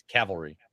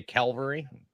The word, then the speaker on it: cavalry: I'm like, the other one that gets me is cavalry. – Cavalry? – Yep. The number of people that say Calvary and they spell it Calvary, C-A-L-V-A-R-Y, instead of C-A-V. It's cavalry, not Calvary.